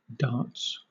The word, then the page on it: darts: British English